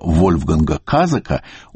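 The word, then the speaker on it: Kasack: Например, когда вышла книга Евгения Шварца с комментариями Лосева, он сочинил для немецкого слависта, ныне, увы, покойного Вольфганга Казака, ударение на первом слоге, такую дарственную надпись.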